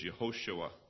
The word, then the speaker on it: Yehoshua: His name is significant because Joshua is the Hebrew pronunciation for the word, well, Hebrew, our English is Joshua, but the Hebrew is Yehoshua. That's Hebrew.